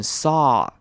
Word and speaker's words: saw: 好，看到下一句 I just saw you，这边 saw 要特别注意 AW 的声音是 R，不要念成 O，所以要念成 saw I just saw you。